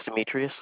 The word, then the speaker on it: Demetrius: My name is Demetrius.